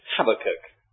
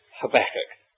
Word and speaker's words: Habakkuk: Some people pronounce it Habakkuk. I don't think it very much matters whether you put the stress on the first syllable or the second syllable, because we don't quite know how the word originally was pronounced. But I have pronounced it Habakkuk.